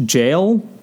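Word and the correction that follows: Jai: White? Am I pronouncing that right? Jay. Jay. Okay, I'm not really actually familiar with him.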